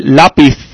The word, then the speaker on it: lápiz: They pronounce it with a, they stick out their tongue, they lisp it. So let me repeat it one more time. Lápiz and lápices. So if you want to talk correct Spanish in Spain, you would put your tongue between your teeth when you pronounce a C or a Z. So let me say it one more time. Lápiz, el lápiz, and los lápices.